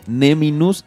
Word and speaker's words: Neminus: Neminus, N-E-M-I-N-U-S. Así es.